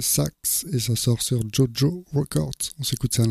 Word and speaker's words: Tjotjo: On va continuer toujours dans les nouveautés, cette fois-ci avec Jojo Records. Ça, je le dis comme ça parce que ça s'écrit T-J-O-T-J-O, Jojo Records, Big World for My Little Bird, et donc c'est de Netherlands.